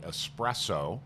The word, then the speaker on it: espresso: Well, and I was always very impressed by espresso, mm-hmm. which is, there's 15 different pronunciations, 14 of them are wrong. – There's no X in it.